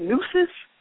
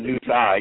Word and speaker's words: nooses: Nooses? – Yeah, that's right. You say nooses, not noose eye.